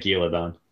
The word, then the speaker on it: Ghealdan: Michael Kramer said Gilladon. Yeah. – He says Gilladon, but it's not spelled that way. – Okay. – I think it's spelled like G-H-E-A-L-D-A-N. – D-A-N, yeah.